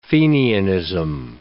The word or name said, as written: Fenianism